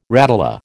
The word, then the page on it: radula: American English